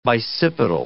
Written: bicipital

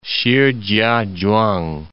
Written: Shih-chia-chuang

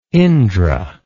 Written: indra

